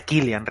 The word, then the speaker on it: Cillian: El nombre se pronuncia Killian realmente, porque es un, el tío creo que es irlandés, ¿no? Me parece. Hmm. Sí. un nombre de estos raros que se, debe pronunciar con una, con una K de estas dura.